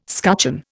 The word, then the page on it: scutcheon: Indian